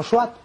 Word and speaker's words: SWT: Bueno, pues sacan este formato que se llama Simple Web Token o SWT, se pronuncia como SWAT para los que sabéis inglés.